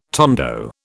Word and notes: tondo: British English

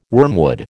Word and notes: wormwood: American English

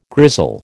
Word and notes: grizzle: American English

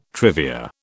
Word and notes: trivia: British English

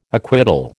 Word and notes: acquittal: American English

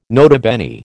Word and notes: nota bene: American English

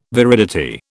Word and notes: viridity: British English

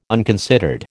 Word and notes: unconsidered: American English

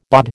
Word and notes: BOD: American English

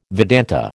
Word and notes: Vedanta: American English

